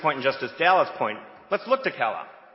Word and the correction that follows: Kalal: K-Law. – K-Law, sorry. K-Law.